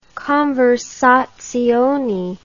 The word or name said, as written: conversazione